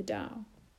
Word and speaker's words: Tao: It discusses that which is very difficult to discuss, that which Lao Tzu called the Tao, pronounced Tao.